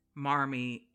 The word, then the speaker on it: Marmee: And how you would say Marmy in a Boston accent, Mommy, which is mommy. It was a phonetic pronunciation of mommy. Which then somehow shifts into proper English as Marmy, which is kind of, I always found that Wow, weird.